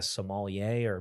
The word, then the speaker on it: sommelier: I think it's sommelier. It's French, but yep.